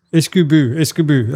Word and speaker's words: Isgubhu: Iskubu. That's how you say it.